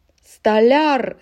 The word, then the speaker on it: столяр: Столяр. Здесь легко запомнить так же, как маляр.